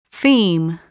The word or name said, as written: theme